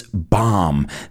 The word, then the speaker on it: bomb: I started out by saying this bombshell, and remember that B is silent. It's not bomb, it's bomb.